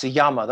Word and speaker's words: Jammer: I mean, I was recently reading a biography of Einstein by one Max Jammer. That's spelled Jammer. It's a German word. J is pronounced as a Y.